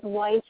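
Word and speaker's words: Whyte: My last name is White, and it's spelled with a Y, W-H-Y-T-E.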